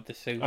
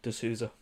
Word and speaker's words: D'Souza: I am saying that right, you know, D'Souza. – I believe so, yeah. D'Souza. – D'Souza. We'll go with D'Souza.